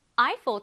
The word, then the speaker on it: Eiffel: 에펠이 아니라 영어로는 Eiffel이라는 발음 잘 기억하고 한번더 읽어볼까요? Eiffel Tower.